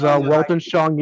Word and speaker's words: Weltanschauung: Well, yeah, German is Weltanschauung, Yiddish is Weltbahnum.